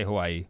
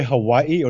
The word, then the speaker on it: Hawaii: How do you say Hawaii? – You can say Hawaii or you can say Hawaii. – Ooh, Hawaii. – Yeah, V or a W sound.